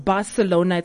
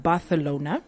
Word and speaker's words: Barcelona: It's actually not pronounced Barcelona. It's actually pronounced Barcelona.